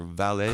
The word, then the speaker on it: Valais: Now, 2 years prior to that, I had skied in a place called Four Valleys or Valais. – Correct. – If you'd say that in French, I guess is the pronunciation there.